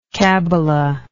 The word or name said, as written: cabbalistically